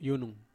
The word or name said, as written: unum